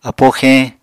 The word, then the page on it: apó xeñ: Apatani